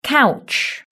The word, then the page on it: couch: british-english